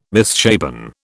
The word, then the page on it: misshapen: British English